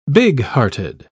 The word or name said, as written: bighearted